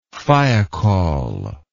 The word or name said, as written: thiokol